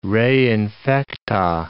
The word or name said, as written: re infecta